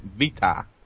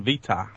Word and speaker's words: Vita: And I think it's pronounced Vita. – Vita. Should be like Vita, like vitamins, crappy thing. – No, like PlayStation Vita, the new NGP name.